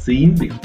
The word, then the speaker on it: Zion: Well, no, but the thing is, everyone pronounces Zion always the wrong way. I mean, I've given up on this because usually, normally it would be pronounced Zine from the word magazine.